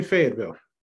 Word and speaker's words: Fayetteville: It's definitely Fayetteville.